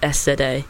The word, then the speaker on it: SZA: And it is by SZA, which is just SZA. Which for a long time I was saying it wrong.